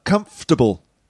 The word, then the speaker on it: comfortable: Comfortable, yeah. Not comfortable, but comfortable. – Comfortable. – Comfortable. Three syllables, yeah. Not four.